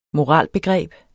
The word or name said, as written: moralbegreb